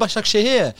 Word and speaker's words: Başakşehir: It's Istanbul Başakşehir.